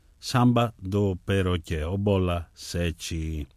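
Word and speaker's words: Sete: Γράφεται Σέτε, προφέρεται Σέτσι, παρατσούκλι που το πήρε από τις 7 μπάλες του μπιλιάρδου.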